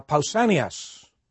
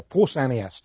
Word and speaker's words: Pausanias: The Greek writer Pausanias, or Pausanias, depending on the pronunciation you like, had described it as one of the wonders of the world.